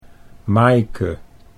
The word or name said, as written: Maaike